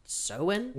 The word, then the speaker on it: Samhain: Samhain is pronounced Samhain. – Well, it's Gaelic, so.